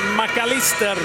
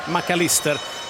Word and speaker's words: McAllister: Till exempel har Andrea hört av sig och sagt, varför uttalar ni inte spelarna som de verkligen uttalas i sina respektive länder, till exempel McAllister. De säger McAllister i Argentina, de säger inte McAllister, även om man har ett engelskklingande namn.